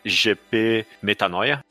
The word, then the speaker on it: Metanoia: É GP Metanoia, essa é a pronúncia.